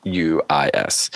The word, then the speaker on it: Nijhuis: And it's by Michelle Nijhuy, I believe is how you say her name. I should have double checked that before I said it, but it's Nijhuis.